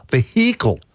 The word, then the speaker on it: vehicle: used to say vehicle, vehicle. – Yep. – Like very prominently pronounce the H.